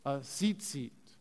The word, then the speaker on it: tzitzit: All right, and then this is a tzitzit. It's like a T and then zit and zit. Again, that's how you spell it. T-Z-I-T-Z-I-T.